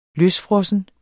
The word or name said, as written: løsfrossen